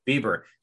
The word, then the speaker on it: Bieber: Bieber.